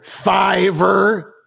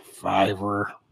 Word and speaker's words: Fiverr: Hey, yeah, I work down the street over at Fiverr, and you are contractually obligated. You have to say it like that. even if you're embarrassed to say it, where do you work? Sorry, say that again. – Fiverr.